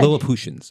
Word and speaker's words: Lilliputians: Lilliputians, I believe is the right way to say it.